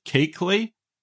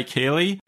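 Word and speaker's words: Keighley: When I interviewed Tony Collins, he pronounced it Keekly, but I feel like I can't say it properly with my Australian accent. So I'm going to say Keely, with the understanding that I know that is wrong, but please don't bombard me with emails about it.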